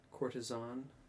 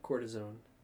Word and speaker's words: courtesan: Courtesan. – Courtesan? – Not cortisone. – It's not a Z. It's an S.